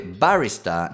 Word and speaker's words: barrister: Barrister. Questa è interessante, barista non è barista. Barista in inglese, R finale, però notate che non lo pronuncio.